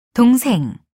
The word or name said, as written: 동생